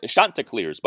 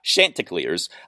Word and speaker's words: Chanticleers: The Coastal Carolina Chanticleers, it is pronounced Chanticleers, by the way, not Chanticleers.